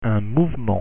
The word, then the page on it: mouvement: French